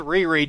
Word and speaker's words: Riri: Yes, Danger, I know her name is pronounced Riri.